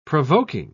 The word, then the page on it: provoking: British English